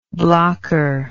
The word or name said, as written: blocker